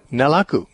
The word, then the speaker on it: Nalaku: Okay, then next caller, I don't know what syllable to put the emphasis on. Is it Nalaku from Seattle? – Yeah, Nalaku, Nalaku, good to hear from you.